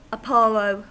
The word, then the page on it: apollo: American English